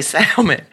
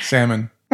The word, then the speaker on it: salmon: But it's called Silly Salmon. – Salmon. That's a constant fight around here. – Look here. It's salmon patties. – Salmon. Oh, that's funny. – The L is present, and I pronounce it.